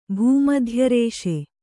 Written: ಭೂಮಧ್ಯರೇಷೆ

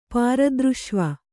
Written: ಪಾರದೃಶ್ವ

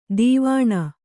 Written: ದಾವಣ

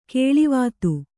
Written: ಕಳಾವತಿ

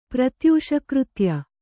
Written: ಪ್ರತ್ಯೂಷಕೃತ್ಯ

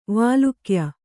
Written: ವಾಲುಕ್ಯ

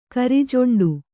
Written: ಕರಿಜೊಂಡು

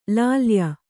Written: ಲುಲಾಯ

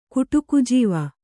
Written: ಕುಟುಕುಜೀವ

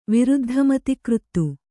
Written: ವಿರುದ್ಧಮತಿಕೃತ್ತು

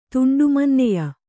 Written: ತುಂಡುಮನ್ನೆಯ

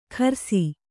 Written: ಖರ್ಸಿ

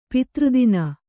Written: ಪಾತ್ರದಾನಿ

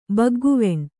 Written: ಬಗ್ಗುವೆಣ್

